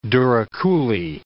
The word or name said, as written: douroucouli